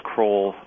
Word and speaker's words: Kroll: but of Julius Kroll. Am I saying that right? – Yes. – Julius Kroll,